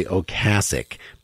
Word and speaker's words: Ocasek: Well, until preparing for this interview, my entire being a fan of him, which makes me also think about Rick Ocasek, whose name is actually Ocasek, but he doesn't care how you pronounce it.